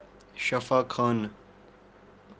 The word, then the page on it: shafakhaanu: kashmiri